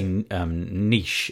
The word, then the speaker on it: niche: So yeah, and it kind of feels that like the we say niche in the UK. We don't pronounce it niche. Mm-hmm. So I'm going to say niche, and I'll try to remember it that way. But if I go straight back into niche, apologies.